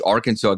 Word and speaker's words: Arkansas: And so they pronounced it Arkansas for so long, I guess, that it just stuck like that.